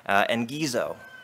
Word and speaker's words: engizo: The Greek word translated as at hand in this verse is, I hope I pronounce this right, engizo. E-N-G-I-Z-O.